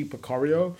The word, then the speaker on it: Pecoraro: I can never say his name right. Joey Picario. – Yeah. – So it's J-O-E-Y space P-E-C-O-R-A-R-O. And the album's called Music for Happiness.